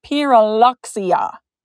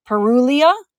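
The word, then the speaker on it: Pyrrhuloxia: It is pronounced Pyrruhuloxia. So you do actually pronounce the X. I have heard some people pronounce it Pyrruhulia. So like you would go over all the X and stuff and leave it out, but apparently you do pronounce the X.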